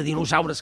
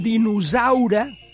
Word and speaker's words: dinosaure: S'ha dit dinosaure i això està mal pronunciat. Se pronuncia dinosaure. És sorda, dinosaure.